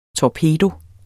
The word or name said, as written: torpedo